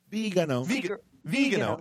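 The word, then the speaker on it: Viganò: But at the same time, it's like, okay, well, all this stuff has come out, and it was planned by Vigano? How do you say his name? – Vigano.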